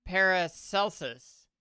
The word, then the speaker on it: Paracelsus: Ooh, I love me a Paracelsus, man. – Mm-mm. Oh, well, I might have been mispronouncing it. His name is Paracelsus.